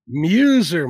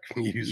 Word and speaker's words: Muzer: Muzer, Mark Muzer.